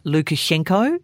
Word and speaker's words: Lukashenko: It's by Melissa Lukashenko. Is that how I pronounce Correct. – It? – Correct.